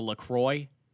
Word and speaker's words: La Croix: Yeah, the LaCroix, $80 per can. – You'd get kicked out for saying that. There, they pronounce it La Croix. – Yes, it's La Croix.